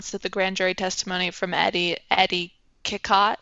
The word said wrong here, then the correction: Cicotte: Is that how you say it? – Uh, Cicotte.